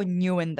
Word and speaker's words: Nyugen: I'm going to say there's no right or wrong because it's technically a Vietnamese name. So the Western pronunciation of it could be up for interpretation. I go for Nyugen though, usually.